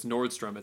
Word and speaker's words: Nordstrom: I guess I'm saying it wrong. I always say Nordstrom's. It's Nordstrom. It's never Nordstrom's.